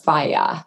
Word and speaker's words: Fage: I believe it's pronounced Faye. Okay, F-A-G-E.